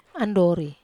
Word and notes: andori: Wamesa

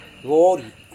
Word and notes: vori: Serewen

